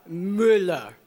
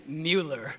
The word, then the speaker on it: Müller: So just to clarify, I asked our resident German scholar, Andrew Huang, how to pronounce the name with the umlaut, and he said it's pronounced Müller. You have to make a little O with your mouth, right? Almost like the Chinese word for fish, Müller, right?